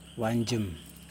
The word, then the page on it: wandJim: Yokoim